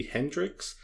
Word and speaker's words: Heynderickx: The musical artist Haley Hendricks the Hendrix is spelled really strangely. So it's Haley, and then Hendrix is HEYNDERICKX.